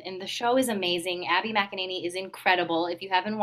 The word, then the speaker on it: McEnany: I said her name right, right? – McEnany? Yeah, Abby McEnany. Yeah.